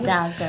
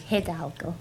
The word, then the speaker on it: Hidalgo: It's how you pronounce Hidalgo. – It? Hidalgo. I'm going to just... – Hidalgo.